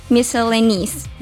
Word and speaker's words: miscellaneous: Itu tuh sebenarnya bukan dibaca nose, tapi bacanya itu nis. Jadi pronunciation-nya itu yang betul, miscellaneous.